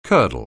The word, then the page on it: curdle: anglais